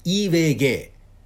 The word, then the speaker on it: JVG: イーウェイゲイ。えー、 JVG と書いてイーウェイゲイと呼びますね。これはあの、フィンランドのアルファベットの読み方が ABC じゃないんですよ。